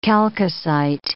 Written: chalcocite

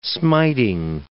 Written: smiting

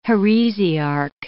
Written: heresiarch

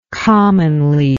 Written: commonly

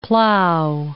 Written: plough

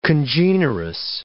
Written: congenerous

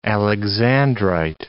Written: alexandrite

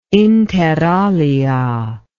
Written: inter alia

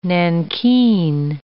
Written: nankeen

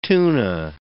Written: tuna

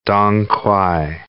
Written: dong quai

